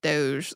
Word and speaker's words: Doge: Yeah, and in Italian, that final E is pronounced a little bit more, but in English, we say it Doge.